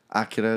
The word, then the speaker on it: Akira: Akira, or Akira. Akira is the right way to say it. – Akira.